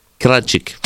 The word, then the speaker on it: krecik: Kretschik. – Kretschik. Hur stavas det? – K-R-E-C-I-K.